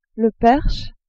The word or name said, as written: perche